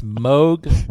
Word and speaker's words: Moog: It's Moog, rhymes with Vogue.